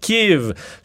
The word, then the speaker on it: Kyiv: Donc, on a dit depuis qu'on est écoutez, Kiev, mais là, ce serait davantage Kiev, ce que vous entendez entre autres sur certains médias américains, donc K-Y-I-V, euh, qui est la prononciation vraiment ukrainienne, tandis que Kiev est davantage la prononciation russe.